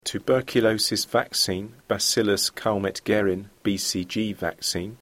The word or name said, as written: tuberculosis vaccine